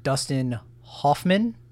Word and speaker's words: Dustin Hoffman: So Mr. Dustin Hoffman.